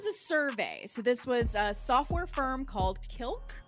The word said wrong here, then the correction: Qlik: Hope I'm pronouncing that correctly. Click. So sorry. I pronounced that wrong. Click. Q-L-I-K.